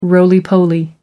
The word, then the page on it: roly poly: American English